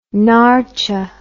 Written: naartje

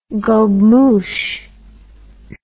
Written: gobemouche

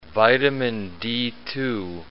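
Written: vitamin D2

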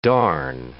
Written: darn

